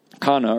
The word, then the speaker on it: Cana: On the third day, a wedding took place in Cana in Galilee. Jesus' mother was there, and Jesus and his disciples had also been invited to the wedding. Cana, or Cana as it's often pronounced, was a city located walking distance from Nazareth, the hometown of Jesus.